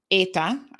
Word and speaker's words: Eta: So that was Tropical Storm Eta, I think is how she was properly pronounced.